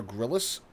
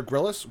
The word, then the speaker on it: Grylls: It's, I added one extra syllable. Grylls. Sorry, it's not there. The I isn't there.